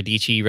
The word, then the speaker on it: Dici: Big shout out to Sarah Dici, rhymes with peachy.